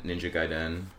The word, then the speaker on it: Gaiden: And Ninja Gaiden.